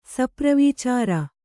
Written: ಸಪ್ರವೀಚಾರ

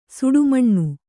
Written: ಸುಡುಮಣ್ಣು

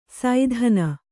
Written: ಸೈಧಾನ

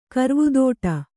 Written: ಕರ್ವುದೋಟ

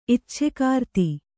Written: ಇಚ್ಛೆಗಾರ್ತಿ

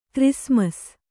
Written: ಕ್ರಿಸ್ಮಸ್

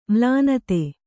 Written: ಮಲ್ಲಿನಾಥ

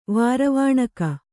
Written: ವಾರವಾಣಕ

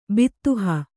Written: ಬದಹು

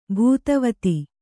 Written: ಭೂತವತಿ

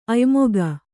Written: ಅಯೋಮುಖ

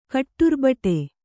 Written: ಕಟ್ಟುರ್ಬಟೆ

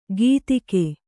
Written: ಗೀತಿಗೆ

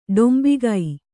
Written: ಡಾಂಬಿಕ

